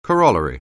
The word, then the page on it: corollary: anglais